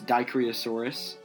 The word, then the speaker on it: Dicraeosaurus: Alright, so it's Dicraeosaurus. If you want to know how to spell that, that's D-I-C-R-A-E-O-S-A-U-R-U-S.